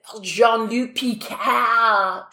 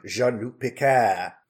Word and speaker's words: Jean-Luc Picard: I'm just going to give my rating, and I'm going to give it 8 out of 10 ways that Vadic said, Jean-Luc Picard. – She's the one person who actually pronounces it correctly. – Exactly. – Jean-Luc Picard.